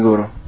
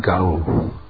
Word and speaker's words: guru: Так действует гуру. – Not Gauru. – Но не Гауру.